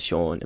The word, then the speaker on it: Sean: When I was in Bali, I'd tell people my name's Sean, and for some reason they just can't pronounce it. They're like, okay, Sean. I'm like, yeah, Sean. They're like, okay, John.